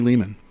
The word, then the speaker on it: Lehman: Ari Lehman, Rhymes with Demon.